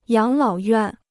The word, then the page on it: 养老院: yǎng lǎo yuàn